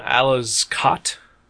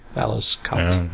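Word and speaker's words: Aleš Kot: No, Alice Kott. – Okay. – A-L-E-S-K-O-T. Alice Kott.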